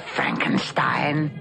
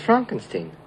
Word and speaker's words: Frankenstein: Dr. Frankenstein. – Frankenstein. – You're putting me on. – No, it's pronounced Frankenstein.